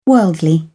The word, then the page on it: worldly: British English